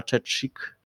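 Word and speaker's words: Chechik: to kiedyś słuchał podcastów w tym Adriana Czeczik i tutaj właśnie tak się wymawia jej nazwisko, niektórzy, niektórych to bardzo dziwi.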